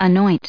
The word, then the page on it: anoint: American English